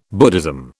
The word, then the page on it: Buddhism: British English